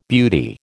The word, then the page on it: beauty: American English